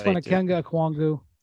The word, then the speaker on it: Onyeka Okongwu: Number six, Okenye Okwongwu. Number six, Onyeka Okongwu. – I mean, this is the worst pronunciation of his name of all time. – Onyeka Okongwu. Sorry.